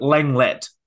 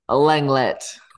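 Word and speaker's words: Lenglet: Is that how you say his name? – From we'll Barcelona. Go with it. We'll put that in the canon. – French. Should we just do it? Langlet. – Langlet.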